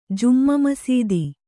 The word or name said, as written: ಜುಮ್ಮಾಮಸೀದಿ